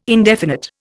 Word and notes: indefinite: Indian

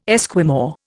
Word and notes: Esquimau: Indian

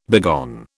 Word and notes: begone: British English

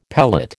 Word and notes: pellet: American English

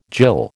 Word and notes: jill: American English

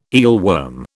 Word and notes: eelworm: British English

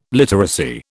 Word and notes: literacy: British English

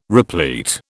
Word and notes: replete: British English

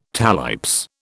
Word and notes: talipes: British English

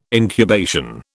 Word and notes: incubation: British English

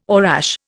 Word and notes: orache: Indian

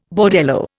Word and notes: bordello: Indian

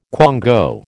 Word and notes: quango: American English

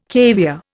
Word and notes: caviare: Indian

